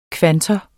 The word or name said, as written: kvantor